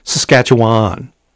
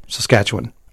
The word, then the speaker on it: Saskatchewan: Because a lot of people say Saskatchewan. They overpronounce that last syllable. – Why, what? How is it supposed to be said? – Saskatchewan.